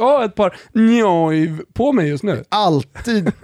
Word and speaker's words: Neuw: N-E-U-W, alltså Neuv blir det på tyska.